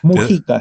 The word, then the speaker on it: Mujica: Mujica.